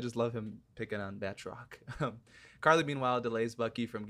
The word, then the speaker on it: Batroc: That's how you say his name, right? Batroc? Or is it Bartroc? – It's Batroc.